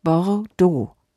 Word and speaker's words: Bordeaux: Die Aussprache von Bordeaux ist Bordeaux.